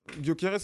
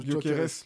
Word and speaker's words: Gyökeres: Gyökeres, c'est comme ça qu'on prononce. – Ouais, c'est comme ça qu'on prononce. – Gyökeres, l'attaquant suédois du Sporting Portugal, qui a 13 buts en 10 matchs, il me semble.